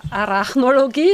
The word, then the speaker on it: Arachnologie: Und Ar Arachnologie, ja, das ist ja. richtig gesagt.